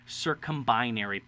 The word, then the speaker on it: circumbinary: One is a very large star, and one is a smaller, dimmer star, but it's known as a circumbinary planet.